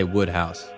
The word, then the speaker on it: Wodehouse: Woodhouse or Wodehouse? – Yeah, I might be saying that wrong. – They say Woodhouse. – I thought it was. – I don't know. – I always thought it was Wodehouse.